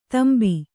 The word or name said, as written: ತೆಂಬ